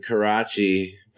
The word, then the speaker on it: Karachi: Yeah, from Karachi, Pakistan. That's how you pronounce it correctly.